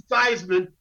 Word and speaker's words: Theismann: His name was Theismann. He changed his name to Theismann to rhyme with Heisman.